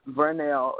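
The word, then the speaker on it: Vernelle: So my name is Vernell. It's spelled with a V, like in Virginia, and I live in Virginia. – Oh, okay. – Yeah. – Vernell. – There's an E on the end, too.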